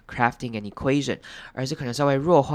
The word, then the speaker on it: equation: 那注意到下一个字是 equation，这个字要注意的是中间的 UA 要念成 QA，念成 U 的声音再念成长母音 A，最后念成 equation， equation，然后重音要放在 UA 这边上面， equation， equation，后面 TION 如往跟以前一样念成人的声音， equation， equation。